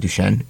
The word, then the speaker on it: Duchene: Matthew Shen's having a core injury, so he's going to have surgery. – Who? – Matt Duchene. – Duchene. – You really pronounce that? – Duchene.